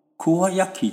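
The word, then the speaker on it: 鍬焼き: 奇怪，叫做北斋呢，啊，北斋福山不是在回头那个福山嘛，哈，啊，结果呢，我就比较讲哦，啊，原来啦，以这种叫做秋烧，kuayaki，秋呢就是一个金，一个秋，秋天的秋，哦，这是日本的汉字，叫做读作 kuayaki 就是秋烧，那么秋烧呢，是因为。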